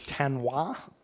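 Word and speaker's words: Thanh Hóa: Two of them were ordained in the Diocese of Thanh Hoa, Vietnam.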